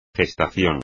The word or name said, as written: gestación